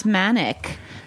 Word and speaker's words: Maniec: I'm about to give that name up, but it's Maniec.